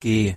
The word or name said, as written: g